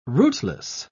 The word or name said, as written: rootless